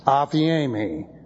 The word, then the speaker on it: aphiemi: And then we have a verb again, the present active indicative of the verb aphiemi. Looks like this. That would be A-P-H-I-E-M-I, pronounced aphiemi.